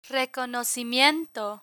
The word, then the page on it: reconocimiento: Spanish